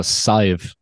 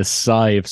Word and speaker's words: Sadhbh: S-A-D-H-B-H. – Yeah. So Sive. – What? – Yeah, Sive. So if you were to, phonetically, it would be S-I-V-E. – Sive. – Sive. But in Gaelic, it's, very different.